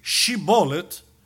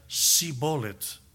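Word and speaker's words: Shibboleth: Los que no pertenecían al grupo, a la entrada les decían di Shibolet, y aún se esforzara uno para decir Shibolet, su idioma, su capacidad fonética no tenía ese sonido, y decía Sibolet, Y ahí eran llevados a la muerte.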